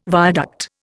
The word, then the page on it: viaduct: Indian